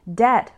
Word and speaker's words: debt: Debt refers to money you need to pay back. Note that the B is silent. It's pronounced debt.